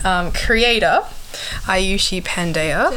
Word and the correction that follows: Pandeya: um, actually, I should pronounce my name properly. Um, Aayushi Pandya, and I am a Nepalese immigrant.